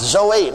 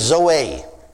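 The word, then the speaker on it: Zoe (ζωή): It is pronounced Zoe. Now I'm not a Greek scholar. But the professor of Greek in Baylor University in Waco, Texas Baptist Seminary told me that that's the way you pronounce it, is Zoe.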